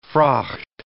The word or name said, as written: fraught